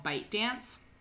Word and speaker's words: ByteDance: So it was a Chinese video sharing social network service um, that was owned by a company called ByteDance.